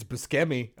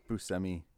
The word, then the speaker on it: Buscemi: Don't tell me it's Buscemi. I'll no, it's always... he says it's Buscemi. – Buscemi. – But the proper way to say it is Buscemi.